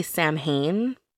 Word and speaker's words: Samhain: It is spelled S-A-M-H-A-I-N. So some people say Samhain. Because that's how Americans would pronounce it. – Because we butcher everything. – Well, it's from the Celtic language. – Right. – So it's pronounced Samhain.